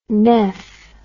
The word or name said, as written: neph